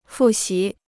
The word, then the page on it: 复习: fù xí